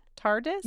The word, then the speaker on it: TARDIS: TARDIS? – Yes, that's how you say it.